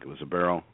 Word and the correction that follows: Eberle: Is it? How do you pronounce it? My pronunciation is garbage. – Jordan Eberle. Eberle. – Like the Eberle brothers.